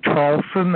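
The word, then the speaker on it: Traulsen: It's Hugh, H-U-G-H, last name Charlson. That's spelled T like Tom, R-A, U-L-S like Sam, E like Edward, N like Nancy, and then just put Twitter after my name.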